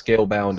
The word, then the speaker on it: Scalebound: Exhibit A, if I could bring all of your attention to the screen here, it says Scalebound delayed to 2017.